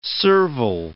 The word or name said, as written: serval